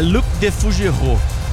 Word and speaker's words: Luc de Fougerot: Mas como é a pronúncia aí? – É Luc de Fougerot.